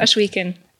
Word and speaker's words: Oshweken: Eigentlich heißt es Oshweken. Also es hört sich eigentlich. – Ja, ziemlich gut. Wie spricht man? Oshweken. Yeah. Ach echt, spricht man es echt so aus? – Ja.